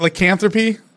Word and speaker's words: Lycanthropy: Did I say that right? Lycanthropy? – Yes.